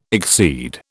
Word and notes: exceed: British English

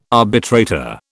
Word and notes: arbitrator: British English

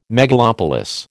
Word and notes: megalopolis: American English

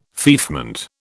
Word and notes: feoffment: British English

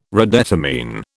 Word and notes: redetermine: British English

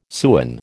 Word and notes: sewin: American English

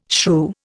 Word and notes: shrew: Indian

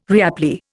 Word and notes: reapply: Indian

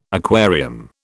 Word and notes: aquarium: British English

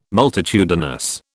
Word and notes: multitudinous: British English

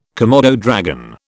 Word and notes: komodo dragon: British English

